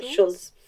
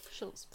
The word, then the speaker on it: Schulz: Is that how you say it? – Yeah, Schulz. – Schulz.